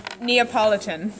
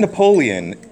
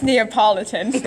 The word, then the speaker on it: Neapolitan: Can you say it like Hermione? – Neapolitan. – It's not Napoleon. – It's Neapolitan.